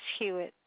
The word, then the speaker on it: Hewitt: Yes, Janice Hewitt. – Could you spell that for me, please? – Janice, J-N-I-C-E, Hewitt, H-E-W-I-T-T.